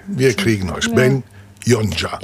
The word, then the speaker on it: Yonca: Y-O-N-C-A. Das ist wichtig. Ben kann man sich gut merken. Aber Yonja mit Y und dann ein C. Also wenn es Ben Yonja, aber nur mit C-A ausgesprochen. Oder Zusatz geschrieben, aber ausgesprochen anders.